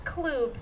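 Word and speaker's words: klubb: It's pronounced Kloob, sweetie.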